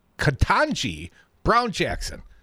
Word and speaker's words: Ketanji Brown Jackson: But a Supreme Court judicial nominee, President Joe Biden, has nominated Judge Ketanji Brown Jackson.